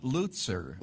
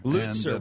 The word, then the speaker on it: Lutzer: How do you say it, Pastor? – I call it Lutzer. – Lutzer. Well, what do you know? – That's the correct pronunciation.